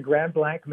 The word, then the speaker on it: Grand Blanc: That's exactly the way everybody pronounced it. They did not pronounce it Grand Blanc, which literally means great white. Mm-hmm. Or if you pronounce it, you know, like the way we pronounce it, blank, it is in fact a fairly anonymous, kind of relatively blank spot in the central Michigan landscape.